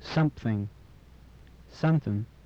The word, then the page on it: something: English